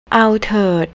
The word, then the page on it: awterd: thai